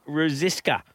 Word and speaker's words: Ruzsicska: And we're going to have a chat to Karen Ruziska. Gee, I hope I've pronounced that right, Chris. – I reckon you're pretty much spot on.